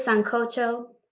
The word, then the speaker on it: sancocho: Let me tell you, I did sancocho. That's how you say it. – Sancocho. Yeah. You said it, sancocho. – I know, you don't say it like an American.